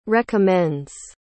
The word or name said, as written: recommends